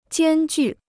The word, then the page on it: 艰巨: jiān jù